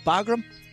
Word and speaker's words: Bagram: Is that, did I say that correctly, chaps? Bagram? – Yeah.